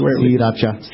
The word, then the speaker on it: Sriracha: So it's from a town in Thailand called Siracha. So if you were Thai, you'd break it with like these three distinct syllables. – Siracha. – Siracha. – So the first R is actually not pronounced in Thai? – Correct. – Siracha. – But just here in America, I think everyone just says Sriracha.